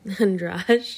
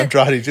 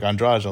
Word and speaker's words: Andrade: I used to call her Andrade, too, and then they were like, Andrade.